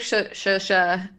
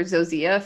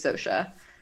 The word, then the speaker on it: Zosia: I've been calling her Zosia for a long time, but I think it's Shosha.